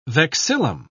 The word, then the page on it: vexillum: British English